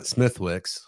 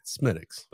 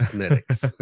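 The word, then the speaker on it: Smithwick's: No, it's not Smithwix. – Right. – Smittix. – But I agree with that. But for some reason, I thought there was someone who's like, no, it's like... – Unless their name is fucking O'Malley, I wouldn't trust them. – No, right. – Because I've heard a couple of them, and it's Smittix.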